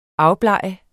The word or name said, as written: AFBLEGER